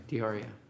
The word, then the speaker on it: D'Auria: Did I just say your last name correctly? Close enough. Okay. Why don't you say it correctly? – D'Auria. – D'Auria. D'Auria. I've been saying it wrong for a while now.